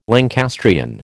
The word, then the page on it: Lancastrian: American English